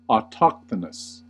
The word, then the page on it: autochthonous: American English; cot-caught merger